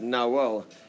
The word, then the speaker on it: Nahual: In Mesoamerican folk religion, a Nahual, pronounced Nahuel) is a human being who has the power to shapeshift into their total animal counterpart.